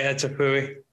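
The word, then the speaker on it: étouffée: I'll never learn how to say etouffee. – Etouffee? – That's actually kind of easy. I guess I could learn that. – It really is. It's three syllables.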